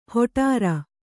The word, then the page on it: ಹೊಟಾರ: hoṭāra